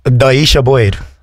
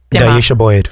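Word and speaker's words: Dw i eisiau bwyd: Doisha Boyd. I'll tell you how it's spelt. – Czech Republic. – It's not the Czech Republic. It is spelt DW space I space EISIAU space BWYD. Doisha Boyd.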